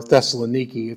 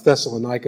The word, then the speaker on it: Thessaloniki: Thessalonica, or Thessaloniki, if you've been there, and that's the way they pronounce it. But Thessalonica is what I grew up with, so I'll keep using that.